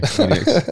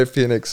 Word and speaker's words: Penix: Penix. I used to say Phoenix too. – Penix. It sounds like it's spelled like that.